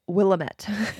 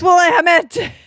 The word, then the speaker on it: Willamette: Now, I was recently listening to an audiobook of an Anne Rule book and the narrator kept pronouncing it Willamette. And so I was screaming at the audiobook. – Yes. – It's Willamette.